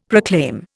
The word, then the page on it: proclaim: Indian